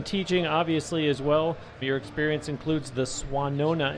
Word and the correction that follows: Swannanoa: if I'm saying that correctly. – Swannanoa.